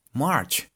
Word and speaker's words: March: Ahora, si esta pronunciación se les hace un poco conocida, es porque son fanáticos de Los Simpson. Si han visto Los Simpson, conocen que la esposa de Homero se llama March.